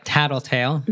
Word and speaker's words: tattletale: So we would say it like tattletale, with an American T on those first T's.